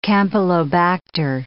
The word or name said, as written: campylobacter